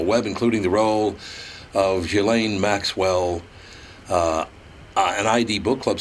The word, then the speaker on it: Ghislaine: And I still, Barry, how do you say, is it Ghislaine? Is that how you say her name? – Television has, says a million different things, but it's pronounced Ghislaine Maxwell. – So it is Ghislaine, okay.